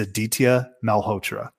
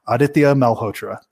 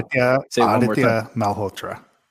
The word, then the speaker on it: Aditya Malhotra: I mispronounced his name to try and make you guys feel more comfortable. Aditya Malhotra. That's how you do it.